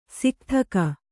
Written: ಸಿಕ್ಥಕ

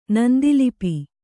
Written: ನಂದಿಲಿಪಿ

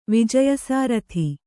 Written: ವಿಜಯಸಾರಥಿ

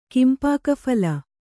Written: ಕಿಂಪಾಕಫಲ

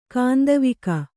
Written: ಕಾಂದವಿಕ

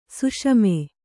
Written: ಸಾಸಿಮೆ

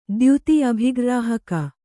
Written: ದ್ಯುತಿ ಅಭಿಗ್ರ್ರಾಹಕ

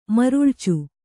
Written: ಮರಳಿಚು

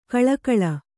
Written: ಘಳಘಳ

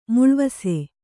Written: ಮೇಲ್ವಾಸು

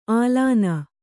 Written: ಆಲಾನ

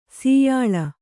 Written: ಶ್ಯಾಲ

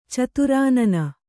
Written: ಚಿತ್ರಾನ್ನ

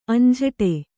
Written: ಅಂಝಟೆ

